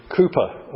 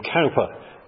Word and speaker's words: Cowper: One such person who suffered depression for most of his life was that well-known hymn writer, William Cowper, or William Cowper, depending on how you wish to pronounce C-O-W-P-E-R.